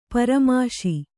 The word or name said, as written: ಪರಾಮೋಶಿ